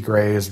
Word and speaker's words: Grey: For the sake of critical thinking and such and that, I recommend people go check out CGP Gray's videos on the Electoral College. – Good idea. – It's CGP, just the three letters together, and then Gray, but it's spelled with an E. It's the G-R-E-Y.